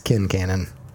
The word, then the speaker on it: Kincannon: I thought it was Quincannon. It is not. It is Kincannon, which sounds way cooler.